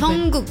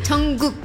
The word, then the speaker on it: Jungkook: No, no pronunciamos la K, al final no, ajá, se pronuncia. – Es súper cortito, super. Jungkook. – Jungkook. Como decir chongo, me decía alguien por ahí. – Sí, más o menos. – ¿Algo así? Chongo. – Chongguk. – Chongguk.